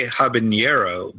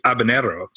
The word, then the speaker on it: habanero: People often say habanero, but technically that's incorrect. It's actually habanero. So you say habanero.